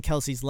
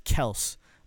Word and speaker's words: Kelce: that Travis Kelce and Jason Kelce's last name is not actually pronounced Kelce. It's pronounced Kels, but everybody always from the time he came into the league said Kelce, so it just, instead of correcting everybody, he just lets it go.